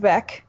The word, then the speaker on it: Veeck: It's pronounced Beck. – Beck. As in wreck.